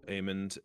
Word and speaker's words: Aemond: more about specifically Aemond. I don't know how to say his name. – Aemond.